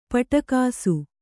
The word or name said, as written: ಪುಟಗೋಸಿ